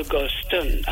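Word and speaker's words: Gulston: Mr. Guston, am I pronouncing it right? – Yes, my dear lady.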